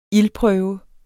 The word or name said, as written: ildprøve